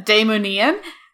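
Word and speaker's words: Daimonion: Again, those books are Magic or Die and, oh my goodness, the pronunciation dropped out of my head, Daemonian. And that's D-A-I-M-O-N-I-O-N.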